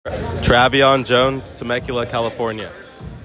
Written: Travion Jones

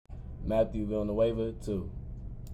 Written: Matt Villanueva

